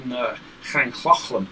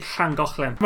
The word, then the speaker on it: Llangollen: I think they finally wound up, it was in Llangollen. Is that how you pronounce it? – No, it's actually Llangollen.